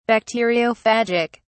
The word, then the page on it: bacteriophagic: British English